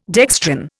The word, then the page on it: dextran: Indian